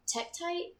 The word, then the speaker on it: tektite: Tektite.